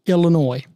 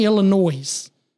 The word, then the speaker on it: Illinois: Got on to me for saying Illinois. Said the S is silent.